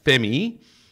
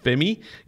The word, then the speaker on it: F3MIII: coming up on number 37 is an artist known as Femi, and it's spelled F3MIII, and it's known as Femi.